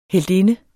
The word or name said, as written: heltinde